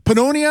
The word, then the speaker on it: Pannonia: Hey Lizzie, how long have you been involved eh, with the, this pavilion with the Hungary P- Pannonia? Am I saying that correctly? Yes, you are.